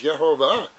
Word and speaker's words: Yehovah: substantiating the name of God is Yehovah. 26 ancient rabbis have said that this is the way to pronounce the name of God, including one of the church fathers.